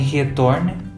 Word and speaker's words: Giethoorn: Tem também Retorne, se escreve Giethoorn e ele é pro leste da Holanda,